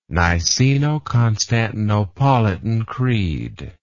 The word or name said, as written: niceno constantinopolitan creed